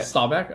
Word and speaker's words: Staubach: I don't know how to say this man's name. Roger Staubach? – Yeah. – Staubach? Okay.